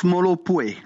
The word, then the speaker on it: Molopwe: I think you could first of all pronounce the word for me: Molopwe. – Oh, that's Molopwe.